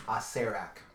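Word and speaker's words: Acererak: I say Asarak, but then there's two R's though. – There are two R's, but the pronunciation guide says it's Asarak.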